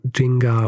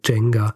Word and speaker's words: Nzinga: Aussprache ist, glaube ich, grundsätzlich sowas in Richtung Jinga oder Jenga. Äh, Haywood spricht auch manchmal von, äh, von Jenga, aber ich glaube, das hängt einfach nur äh, davon ab, wie schnell sie spricht.